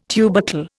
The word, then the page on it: tubercle: Indian